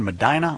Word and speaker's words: Medina: If it's supposed to be pronounced Medina, I apologize. I never get those right. – Yeah, it's pronounced Medina. – Nah! – Everyone else calls it Medina. – Yeah, like a normal person, but not a person from Ohio.